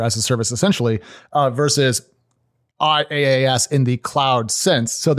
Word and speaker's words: IaaS: Because I'm a child, I want to point out that it is pronounced IaaS.